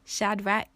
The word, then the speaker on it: Shadrach: How do you say their names? Shadrach, Meshach, and Abednego. That's how you say it.